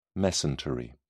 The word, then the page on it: mesentery: British English